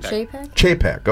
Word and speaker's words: Chapek: Bob Chapek? Chapek? What is it? – Chapek.